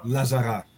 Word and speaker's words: La Zarra: Lazara. Yeah. – yeah. – La Zarra.